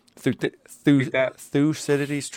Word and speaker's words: Thucydides: Thucydides' trap, I think is how you say it. – Yes.